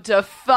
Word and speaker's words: Dafoe: I was like, oh, Willem Dafoe. – I was putting the emphasis on the duh and not the foe.